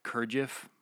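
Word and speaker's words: Gurdjieff: Kurdjieff.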